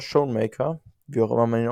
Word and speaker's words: Schoonmaker: Ich kenne ihn als Schoonmaker, aber. Schoonmaker. – Ja. – ja. – Okay, dann nehmen wir das so.